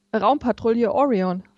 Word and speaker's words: Raumpatrouille Orion: Raumpatrouille Orion.